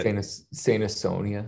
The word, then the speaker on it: Saint Asonia: Saint Asonia.